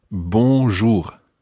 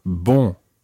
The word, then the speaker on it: bonjour: Now, there are two different sounds in there. And remember last week when we were listening to our nasal sounds, the first syllable is very nasal. It's bon.